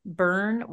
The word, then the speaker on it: Byrne: And on Instagram, she's under Burn Wild Coaching. And Burn is spelled BYRNE, which is her maiden name.